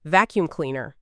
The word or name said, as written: vacuum cleaner